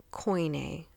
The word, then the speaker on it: Koine: Now, there's a particular Greek dialect that was being spread as this unified, common Greek language, and we call it Koine. It's spelled K-O-I-N-E, Koine Greek.